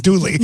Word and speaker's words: Dooley: And so people would be like, oh, your dog's name is Dewey? And I'd say, well, no, it's Dooley. They're just not saying it right.